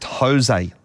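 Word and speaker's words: José: It's pronounced Jose, Lyndall. That's how you say it.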